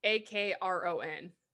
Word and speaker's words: Akron: It's spelled like A K R O N.